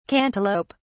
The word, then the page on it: cantaloup(e): British English